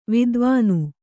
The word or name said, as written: ವಿದ್ವಾನು